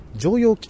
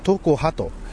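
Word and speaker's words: 常葉: これはですね、桐蔭、大阪桐蔭がですね、甲子園の決勝で自重しなかったと、 17 対 0 とんでもない試合をやったっていうね、相手で試合のことを話したわけで、そのね対戦相手を常陽菊川と、いろはさん連呼してたわけですけども、常に葉っぱと書いて常陽ではなく東高派と読むんだそうでございまして、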